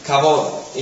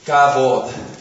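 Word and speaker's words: Kavod (כבוד): Es una palabra hebrea, Kavod. Y se escribe así en hebreo. Kavod.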